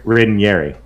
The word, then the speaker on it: Ranieri: It's an I. – Ranieri. – Yes, it's pronounced that way. You're pronouncing it correctly.